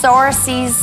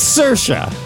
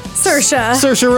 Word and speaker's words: Saoirse: I've had a lot of Sorces, Suarez, Cersei. – It's Saoirse. – Saoirse Ronan. – Yeah. – Yes. – I did try to spell Saoirse earlier. I know now. It's S-A-O-I-R-S-E, right? – S-A-O-I-R-S-E.